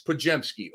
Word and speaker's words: Podziemski: And it's like spelled Podziemski, but the D is silent for everybody out there. It's Podziemski, okay?